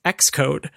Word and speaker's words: Xcode: And that is another one pronounced X, and it's of course Xcode,